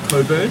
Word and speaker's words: Cockburn: We've got Coburn in Perth. But it's spelled Cockburn.